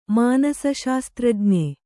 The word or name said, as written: ಮಾನಸಶಾಸ್ತ್ರಜ್ಞೆ